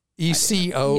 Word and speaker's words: Ecobee: Then there's one other that gets a good rating from Consumer Reports is the, I will mispronounce this, Ecobee. – That's right. – ECOBEE.